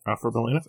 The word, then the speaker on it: Alfred Molina: Alfred Molina.